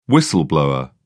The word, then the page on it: whistle-blower: British English